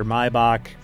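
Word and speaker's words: Maibock: Another substyle is Maybach or Maybach, which is the Americanized pronunciation of it. We won't get into the German one because I won't do it right anyway, which is produced from paler malt, right?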